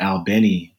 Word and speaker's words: Albany: where locals pronounce it Albany, Georgia.